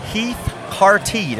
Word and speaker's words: Heath Cartee: All right, so Caleb, here we are with our next guest tonight, and is Mr. Heath Carty.